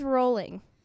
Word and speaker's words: Rowling: Now, do you pronounce that Rowling or is it Rowling? – It is Rowling.